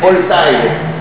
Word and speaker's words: Voltaire: No sé cómo se pronuncia Voltaire, Voltaire. Mi mujer, que habla francés, le putea, pero bueno. – Voltaire. Es doble E, ¿verdad? – Voltaire, sí.